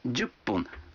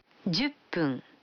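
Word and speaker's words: джупун (じゅっぷん): А 10 минут – джупун. Вы заметили, что когда фун добавляется к 10, дю, то произносится пун. – Послушайте и повторите каждую фразу. 5 минут. – Гофун. – 10 минут. – Дюппун.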